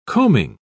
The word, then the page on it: coaming: British English